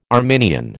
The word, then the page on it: Arminian: American English